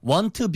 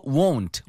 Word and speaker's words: won't: 발음 주의하세요. 이거 want be long이 아니고 won't. Won't. – Oh, yeah. Very strong O sound.